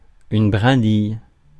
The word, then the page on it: brindille: [bʁɛ̃.dij]; France